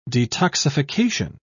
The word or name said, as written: detoxification